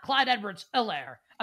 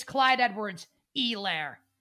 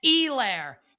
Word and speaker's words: Edwards-Helaire: It's not Clyde Edwards-Helaire. Apparently, it's Clyde Edwards-Helaire. Stupid pronunciation for a stupid player. Again, that's Helaire.